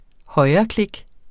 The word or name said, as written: højreklik